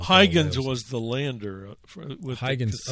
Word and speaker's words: Huygens: Huygens.